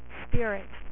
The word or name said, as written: Spirit